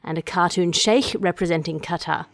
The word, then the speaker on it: Qatar: in the shameful past. Sorry, it's pronounced Qatar.